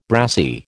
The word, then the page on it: brassie: American English